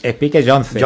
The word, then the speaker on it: Spike Jonze: Spike Jonze, ¿no? – Spike Jonze.